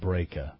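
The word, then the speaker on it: Brejcha: over the Tom Breca, that's B-R-E-J-C-H-A, I'm pronouncing it Breca.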